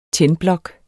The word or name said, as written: tændblok